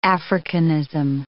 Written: Africanism

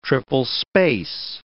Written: triple-space

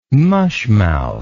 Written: mushmouth

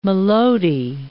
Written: maloti

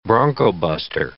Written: broncobuster

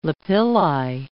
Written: lapilli

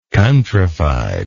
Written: countryfiedness

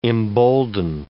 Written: embolden

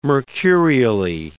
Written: mercurially

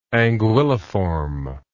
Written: anguilliform

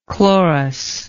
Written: chlorous

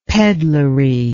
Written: pedlery

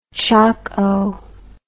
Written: choco

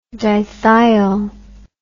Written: dithiol

